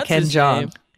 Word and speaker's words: Ken Jeong: Ken, yeah, Ken Jeong, who's also a judge on The Masked Singer.